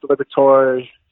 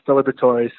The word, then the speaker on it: Liberatore: Usually they forget the E at the end, so they actually don't do too bad. It's usually just Liberatore. Sometimes they butcher the B-R-E, the middle part, but for all of those who want to know, it's Liberatore, so it's not too complicated once you get it down and you say it the first time.